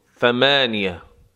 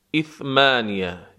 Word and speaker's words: ثمانية: البته اینجور هم استعمال می کنند. اثمانیه. یعنی حرف ساس نقطه را ساکن می کنند. یک همزه اول کلمه اضافه میشه. این چنین تلفظ میشه. اثمانیه.